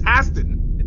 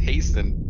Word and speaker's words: hasten: It's Hasten. – It was hubris for me to hasten the day of her arrival. You fucking. – Is it? You pronounce. What I said? The T is silent.